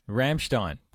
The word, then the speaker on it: Ramstein: Darren wants to see, is it Ramsdon? – Ramstein. – Totally butchered that.